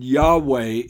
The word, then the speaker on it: Yahweh: Yahweh is the correct pronunciation, I believe.